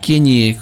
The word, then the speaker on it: Kanye: Es Kanye, como la Ñ, como si fuera el Ñelol.